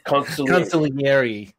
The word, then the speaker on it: consigliere: Gene Ruffini as the old concierge. I believe I said that correctly, did I not? – Consigliere. – Yeah, I get that fucked up all the time.